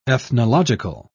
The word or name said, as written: ethnological